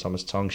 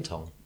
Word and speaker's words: Tonge: That's what you call T-O-N-G-E. Tommy Tong. Tom Tong. – Is there a comedian called Ben Tong? – Probably. – Tong. – Tong. – Tong. – Tong. Yeah. – Tong. – Oh, as in tongue. – But spelled T-O-N-G-E.